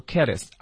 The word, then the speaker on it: careless: 啊，那我们回头再来看一下这个 careless 啊，大家记住 less 那个 e 呀、啊，通常我们读成一个小的对吧？ – 嗯啊，所以就不要读 careless，我们读 careless 啊。